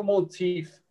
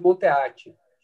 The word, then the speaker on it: Monteath: o meu sobrenome, ele é, tem descendência escocesa. Então, a pronúncia é Will Monteith. Nem eu sei se eu pronuncio tão certo. Mas a gente abrasileirou, né? E minha família, enfim, é, começou a chamar de Monteate. Então, tanto ah. – quanto, quanto Monteate funciona.